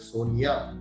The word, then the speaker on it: Sonja: I hope I'm pronouncing the name correctly, but it's written as S-O-N-J-A, pronounced as Sonia.